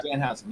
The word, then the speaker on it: Danhausen: Uh, Danhausen, Danhausen, that's how you say it, right? – Yeah. Danhausen, yeah.